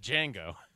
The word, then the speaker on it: Django: I'm the best guitarist in the world, except for that Django. – That's good. The D is silent.